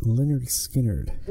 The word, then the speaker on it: Lynyrd Skynyrd: I believe it's pronounced Lynyrd Skynyrd.